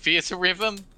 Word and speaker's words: Theatrhythm: A giant chocobo laid an egg and out hatched Theat Rhythm. Final bar line. I'm saying that right. Theat Rhythm? – I don't fucking no, I always pronounced it Theater Rhythm, because that's actually Those are actually words, yes.